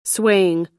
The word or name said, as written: swaying